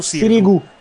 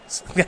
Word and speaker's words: Sirigu: Sirigu o Sirigu? Ho sentito entrambe le pronunce oggettivamente, credo che sia più Sirigu. – Sirigu.